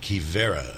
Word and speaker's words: Quivira: And again, it's Q U I V I R A, uh, pronounced Kivera.